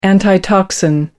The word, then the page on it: antitoxin: American English